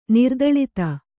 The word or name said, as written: ನಿರ್ದಳಿತ